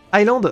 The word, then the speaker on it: Iceland: On ne prononce pas le S de Iceland. Island Ah oui, my bad.